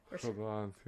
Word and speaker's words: Chabad: Chabad? – Chabad, yeah.